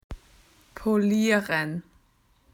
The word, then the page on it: to clean: Australian English